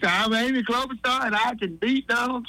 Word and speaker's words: Klobuchar: Klobuchar. – Klobuchar, yes.